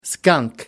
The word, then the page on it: skunk: American English